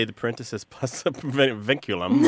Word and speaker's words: vinculum: Vinculum, yeah. It's a great Scrabble word, by the way. V-I-N-C-U-L-U-M.